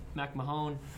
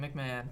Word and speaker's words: McMahon: You made fun of me the way I pronounced his guy's name. Last time he made a report. – McMahon? – It's McMahon. You want to say McMahon? – Tim McMahon. – All right, we'll say Tim McMahon.